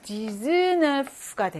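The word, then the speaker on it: dix-neuf: 이것도 10이라고 하는 것은 그냥 단독으로 쓰면 디스라고 발음이 나지만 뒤에 또 다른 숫자 네프와 결합을 하면서 디즈네프가 되죠. 발음이 달라지죠.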